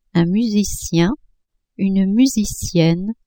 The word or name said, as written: musicien-musicienne